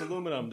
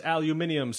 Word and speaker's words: aluminium: That's the only joke I'm going to have of the different pronunciations between Canada, the Europe, and the U.S. about aluminum and aluminium.